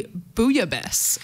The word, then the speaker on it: bouillabaisse: So if you speak French, I'm sorry for my pronunciation, but it is actually bouillabaisse, apparently.